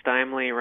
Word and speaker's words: Steimley: Steimley rhymes with timely.